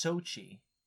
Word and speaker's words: Xochitl: I think it's pronounced Xochitl. – That's cool. – It doesn't look like it, but I'm pretty sure that's how it's pronounced.